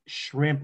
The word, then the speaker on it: Schrempp: Yeah, like it's pronounced shrimp, which is why my podcast is called All You Can Eat Shrimp with Tim Schrempp.